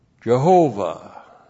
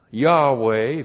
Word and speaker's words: Yahweh: B-A-Y-I-T-H, bayith. That's the Hebrew word house. And then we have our tetragrammaton. Y-H-W-H. Jehovah. Yahweh, if you actually pronounce it.